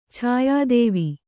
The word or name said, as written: ಛಾಯಾದೇವಿ